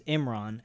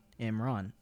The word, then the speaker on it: Imran: It's like M like important and Ron like Ronald. Imran.